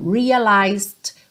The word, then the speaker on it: realized: E esse também é um padrão dessas palavrinhas terminadas em T-I-O-N in English. I realized quickly when I knew I should. Então, realized, cuidado para não pronunciar o E, não é realized, ok?